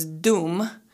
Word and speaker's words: Dum: And what I really like about Durga and her Bija mantra is Dum, D-U-M, Dum.